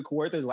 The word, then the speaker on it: Kawartha: So Charlie had the brilliant idea of going out to a cottage in Kawartha's, Ontario. Okay. Did I pronounce that right? – Kawartha. Okay, you got it.